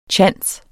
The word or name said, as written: tjans